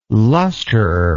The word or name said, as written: lusterer